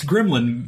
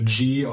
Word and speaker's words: Grimline: It's Gremlin Mitsu, but it's spelled Grimline. So Gremline Mitsu.